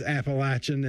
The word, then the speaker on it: Appalachian: I know how to say it. It is Appalachian, and I say it the right way.